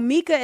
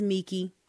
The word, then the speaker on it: Mickey: No, Mika is only when I'm kind of upset. Mickey, well, I'm sorry. – I don't want you upset with me. – Yes, no. I'm kidding. Ron, my name is Mickey, all long E's.